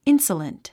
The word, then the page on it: insolent: ínsələnt; インソラント